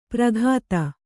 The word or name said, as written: ಪ್ರಘಾತ